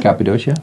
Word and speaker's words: Cappadocia: Cappadocia, yeah.